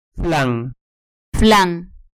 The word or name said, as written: flan